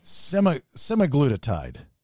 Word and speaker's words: semaglutide: All right, spell that for us. – S-E-M-A-G-L-U-T-I-D-E. – Semaglutide.